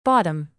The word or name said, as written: bottom